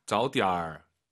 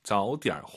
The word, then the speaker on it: 早点: 나 早点回去休息吧. – 와우. 나는 저것이 아니라 그러면이죠. 한 번 더 들어볼게요. 기니까. – 나 早点回去休息吧. – 早点. 点. 올라가면 안 되죠. 뒤에가 이성이니까. 반삼성. 早点回去休息吧.